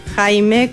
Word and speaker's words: Jaime: Sí, les dejo mis redes sociales, Vanina Jaime. Jaime, como el nombre se escribe, Vanina con B corta, oficial. Por Instagram me pueden encontrar. También en LinkedIn, Vanina Jaime,